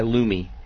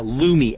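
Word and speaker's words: Lume: Yeah, if you want to buy one, a regular one, buy Lumi. L-U-M-E, I think it is. – Just spell that again. – Yeah, Lumi. L as in Larry, U as in Uncle, M as in Mary, E as in Edward.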